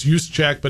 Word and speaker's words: Juszczyk: Juice, yeah, you know, it's Juszczyk, but it's spelled J-U-S-Z-C-Z-Y-K. They call him Juice.